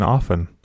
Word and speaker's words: often: I had no idea you pronounced the T in often.